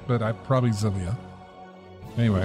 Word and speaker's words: Xillia: I guess it's pronounced Xillia? X-I-L-L-I-A? – Yeah, usually something like that. It does have a Z sound to the front.